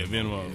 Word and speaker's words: Venmo: Venmo, is that how you say it? – Yeah, Venmo.